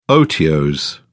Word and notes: otiose: British English

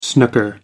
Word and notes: snooker: American English

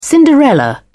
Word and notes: cinderella: British English

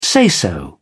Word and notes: say so: British English

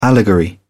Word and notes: allegory: British English

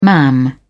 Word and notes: mam: British English

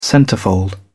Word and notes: centrefold: British English

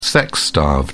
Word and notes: sex starved: British English

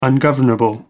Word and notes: ungovernable: American English

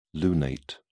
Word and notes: lunate: British English

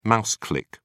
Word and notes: mouse click: British English

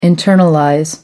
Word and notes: internalize: American English

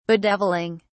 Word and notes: bedevilling: British English

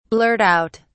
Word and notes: blurt out: British English

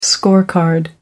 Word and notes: scorecard: American English

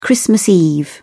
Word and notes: christmas eve: British English

